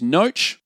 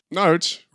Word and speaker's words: Noach: His name is Noach. – Noach. – Rhymes with roach.